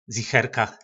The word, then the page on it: zicherka: po našimu